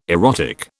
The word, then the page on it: erotic: British English